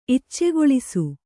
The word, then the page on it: ಇಚ್ಚೆಗೊಳಿಸು: Kannada